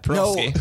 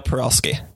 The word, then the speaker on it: Parulski: Parulski. – No. What is it? What do you? Kyle Parulski. – Parulski. – Yeah. – Oh, so it's like a, it's not a per.